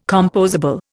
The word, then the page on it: compossible: Indian